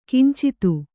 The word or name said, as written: ಕಿಂಚಿತ್ತು